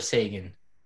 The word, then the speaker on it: Seguin: Tyler Seguin.